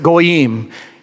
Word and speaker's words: goyim: When it says, for all nations, the word nations in Hebrew is pronounced goyim.